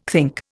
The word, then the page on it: think: Indian